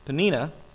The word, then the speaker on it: Peninnah: And whenever the day came for Elkanah to sacrifice, he would give portions of the meat to his wife, Peninnah, that's how you say it, and to all her sons and daughters.